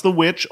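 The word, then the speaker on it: The VVitch: It's pronounced The Witch, although it's spelled with two capital V's instead of a capital W.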